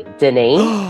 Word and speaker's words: Denis: So it is Denis, like the Denis Mountain in France.